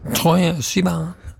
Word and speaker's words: Troye Sivan: Det var Troye Sivan, er det sånn det uttales, eller? – Ja. – Troye Sivan.